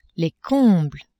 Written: combles

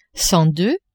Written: cent deux

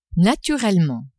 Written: naturellement

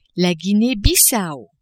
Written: Guinee-Bissao

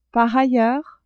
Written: par ailleurs

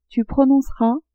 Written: tu prononceras

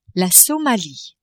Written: Somalie

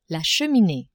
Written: cheminee

